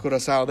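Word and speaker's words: Curaçao: Curacao.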